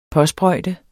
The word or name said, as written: påsprøjte